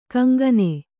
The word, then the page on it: ಕೊಂಕಾನ್: koŋkān